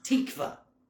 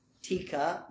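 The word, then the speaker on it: tikva (תקווה): It's pronounced tikva. Tikva. That's a cool word. Some people said, did you name your dog Hope? Tika? No. Tikva. Tikva.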